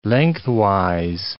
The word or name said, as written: lengthwise